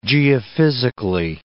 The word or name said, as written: geophysically